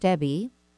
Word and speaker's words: Debby: please reach out to me at Ask Coach Debby.